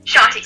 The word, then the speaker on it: Shanti: Shanti, so close.